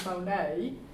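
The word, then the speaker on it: monē (μονή): which is in Greek, is pronounced monē, these two words give us a glimpse of the scope and detail of the place that he's preparing for us.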